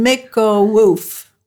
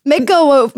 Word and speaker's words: Mikołów: We live in Mikkowów. Woof. – Mikkowów. Mikkowów. – It kind of looks like Mikkelow if you were looking at it in English.